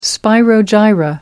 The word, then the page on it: spirogyra: American English